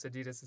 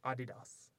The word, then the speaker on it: Adidas: Like, Adidas isn't pronounced Adidas. It's, like, Adidas.